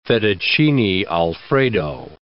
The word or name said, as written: fettuccine Alfredo